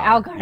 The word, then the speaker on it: Allgeier: One of them is Allgeier, I think I'm saying his name right. – Yeah. – From the Tyler Allgeier. – Allgeier.